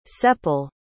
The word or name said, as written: csepel